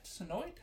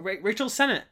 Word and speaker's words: Rachel Sennett: Rachel Sennett? Sennoid? How do you say his name? Rachel Sennett.